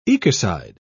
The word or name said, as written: ecocide